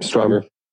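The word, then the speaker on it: Strivr: Is it Striver? – Yep, Striver.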